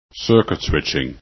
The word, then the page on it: circuits: english